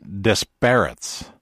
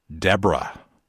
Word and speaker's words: Desbarats: And I said, I think that you pronounce it Desperates, D-E-S-B-A-R-A-T-S, Desperates, Ontario. And he says, let me tell you, by the locals pronounce it Deborah, French origins.